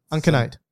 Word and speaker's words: uncinate: Uncanuck? – What? – I can't pronounce it. UNC. How do you pronounce it? – I don't know. You spell it for me. – UNCINATE. – Uncinate.